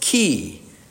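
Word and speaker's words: key (כי): This word, this little word, is pronounced key. That's right, as in a key which is put into a locked door and turned.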